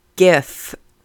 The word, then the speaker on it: GIF: The correct way to say that is GIF.